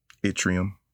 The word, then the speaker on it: yttrium: I believe that's how you pronounce it, yttrium. Am I pronouncing that right, Neil? – Yeah.